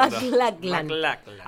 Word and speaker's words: MacLachlan: Dale Cooper, que es Kyle MacLachlan. – Es muy difícil pronunciarlo. – MacLachlan.